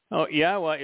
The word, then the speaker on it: Yahweh: Yahweh introduced his name to Moshe in the Torah, and he said that it was based upon the Hebrew verb haya, which means to exist. Haya has two of the, actually three letters comprising Yahweh's name, because the hays are repeated. And so haya tells you how to pronounce it, and it also tells you what it means.